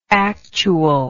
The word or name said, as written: actualness